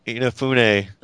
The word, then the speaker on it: Inafune: I was just going through related links to that article where they said Inafune.